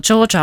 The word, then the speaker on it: Georgia: Sitten on Georgia vai Georgia, miten se nyt pitäisi lausua. Kenenkäs Yrjön mukaan se on saanut nimensä? – Joo, Georgia on oikeastaan se ääntämistapa, jota suositellaan.